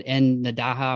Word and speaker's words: En Nadaha: And then you had En Nadaha, am I saying that right? – Yes, you are.